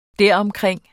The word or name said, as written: deromkring